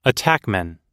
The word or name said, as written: attackman